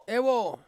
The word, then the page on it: wo: Ansus